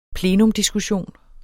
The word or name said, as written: plenumdiskussion